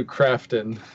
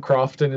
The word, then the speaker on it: Crofton: It's closer to Crafton, but okay, there's no there. – Yeah. – Okay. Just so we know, I've gotten his name wrong right off the bat, and that's not going to change. – Well, if you want to visualize me more German, Crofton is fine.